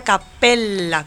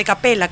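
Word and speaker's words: cappella: Que siempre hay que acordarse, Rafael, de que se pronuncia capella con dos P's. – Ajá. – Porque si no estaríamos hablando de capella, que es una cabra.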